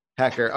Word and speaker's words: Haecker: Haecker.